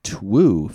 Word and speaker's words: Twoo: T-W-O-O.